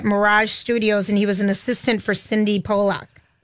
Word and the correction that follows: Sydney Pollack: Am I pronouncing Sydney Pollack? Right? – Sydney Pollack. Sydney, the director.